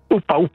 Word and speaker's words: Uppa: Cosa vuol dire Uppa? Si pronuncia così? – Uppa, sì. Allora